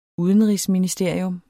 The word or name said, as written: UDENRIGSMINISTERIET